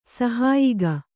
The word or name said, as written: ಸಹಾಯಕ